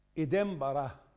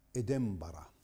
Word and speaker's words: إدنبرة: إدنبرة عاصمة اسكتلندا تنطق بكسر الهمزة وبفتحة خفيفة على الباء، هكذا يلفظها أهلها، وهم مثلنا يجعلون النون ميما في هذه المواضع. إدنبرة.